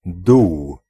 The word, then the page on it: hole: American English; [ˈhoʊl]; RP; /həʊl/; [həʊɫ]